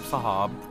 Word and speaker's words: Sahab: I'm Sahab.